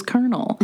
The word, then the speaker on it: colonel: The word of the day is Colonel, not spelled like you'd like it to be spelled.